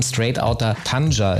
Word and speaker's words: Straight Outta Tanja: Du hast mal gesagt, dein Debütalbum, weswegen wir dich eingeladen haben, Straight Outta Tanja, ist richtig? Habe ich es jetzt richtig ausgesprochen? – Genau, richtig, ja.